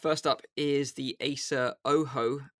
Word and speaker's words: Ojo: It's spelled Ojo, O-J-O, but pronounced Oho. – Oho, yes.